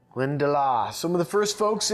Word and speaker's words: Glendalough: How do I say this brand now? – I told you we like to make things hard to pronounce. – Glendalough. – Glendalough. – Glendalough. – So think of your locks here, or think of the Loch Ness Monster.